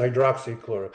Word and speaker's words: hydroxychloroquine: So hydrochloroquine, does it work? – You're saying that wrong. It's hydroxychloroquine.